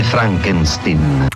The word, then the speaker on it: Frankenstein: a partire da Frankenstein Junior, si legge Frankenstein,